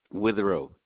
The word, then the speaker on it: Witherow: R-O-B, Rob. – Rob, okay. And that last name? – Witherow, W-I-T-H-E-R-O-W. – And you said W-I-T-H-E-R-O-W? – Yes, Witherow.